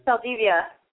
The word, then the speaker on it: Saldivia: I feel like I'm saying his name wrong, but I'm going to try it anyways. Hector Saldivia. Is it Saldivia? Saldivia? – Saldivia. – Saldivia. There we go.